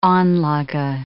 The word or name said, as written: anlage